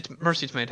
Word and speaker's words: Tomei: Starring a fantastic cast of Christian Bale, not me, Stephen Carell, Ryan Gosling, Marcia Tommy, Mercy Tomei, thank you, and, Rudy, I can't pronounce that last name.